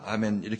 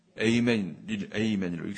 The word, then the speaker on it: 아멘: 헬라어 발음은 아멘인데 우리는 잘난 척하고 에이멘으로 바꿨구나 이렇게 생각하고 다시 이제 아멘으로 바꿀 모양이에요. 근데 우리는 에이멘으로 바꾸려고 하고 있는데 그래서 서로 어긋나는데 실제적으로 아멘이 맞는 거죠. 아멘. 아멘이 맞아요.